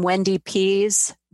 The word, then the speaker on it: Wendy Pease: You can also go to the website, just my name, Wendy Pease.